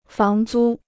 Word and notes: 房租: fáng zū

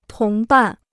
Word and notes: 同伴: tóng bàn

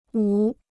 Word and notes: 五: wǔ

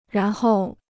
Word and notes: 然后: rán hòu

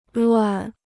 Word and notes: 乱: luàn

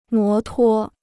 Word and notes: 摩托: mó tuō

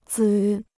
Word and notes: 子: zǐ

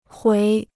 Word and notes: 回: huí

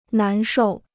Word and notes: 难受: nán shòu

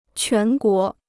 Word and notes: 全国: quán guó

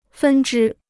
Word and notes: 分之: fēn zhī

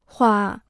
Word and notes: 化: huà

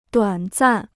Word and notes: 短暂: duǎn zàn

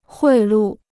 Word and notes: 贿赂: huì lù

